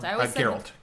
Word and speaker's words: Geralt: Geralt.